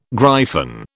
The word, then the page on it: gryphon: British English